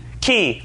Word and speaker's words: chi: Key. It is a key. So it's a key for you Greek scholars, it's a chi for the rest of us fools.